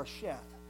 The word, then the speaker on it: Sheth: And then the next name in the list is Seth, or Sheth, as it's pronounced here. It's Seth back in Genesis.